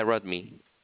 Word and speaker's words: Rodney: Yeah, my name is Rodney. – Rotman? – Yeah, Rodney.